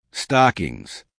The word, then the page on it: stockings: American English